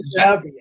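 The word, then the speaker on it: Zappe: It's so bad you have to put in – how do you even say his name? Bailey Zapper? – Bailey Zappi.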